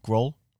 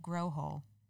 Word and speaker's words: Grohol: Grohl? Like Grohl's? – No, Grohol. Don't laugh.